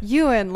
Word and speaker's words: Ewan: Ewan, like a female sheep. Made masculine.